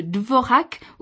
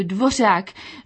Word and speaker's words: Dvořák: Ce matin encore, quelqu'un a parlé de Dvorak au lieu de Dvořák.